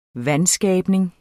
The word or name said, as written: VANSKABNING